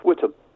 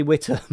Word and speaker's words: Whitehall: That's pronounced Whitehall. – Sorry, Whitehall. I'm not local. I'm from up north.